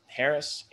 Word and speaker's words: Harris: My handle on both Twitter and Instagram is C-O-N-O-R underscore Harris, H-A-R-R-I-S, and then another underscore after that.